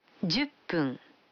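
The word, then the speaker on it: Дзюппун (十分): Вы заметили, что когда фун добавляется к 10, дю, то произносится пун. – Послушайте и повторите каждую фразу. 5 минут. – Гофун. – 10 минут. – Дзюппун.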